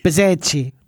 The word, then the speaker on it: Bezzecchi: Is it Bezzecchi or Bezzecchi? – Bezzecchi. – Bezzecchi. – Bezzecchi is how you say it properly.